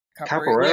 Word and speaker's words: Capoeira: Is it Capoeira? – Capoeira, yeah.